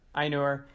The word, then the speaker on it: Ainur: How do you pronounce it? Ainur? Ainur.